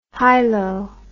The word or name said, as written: hylo